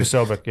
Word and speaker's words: Posobiec: Both sides were, dude, fucking Jack Posobiec's like, Posobiec. – We need to, is that how you say it? – Jack Posobiec, yeah.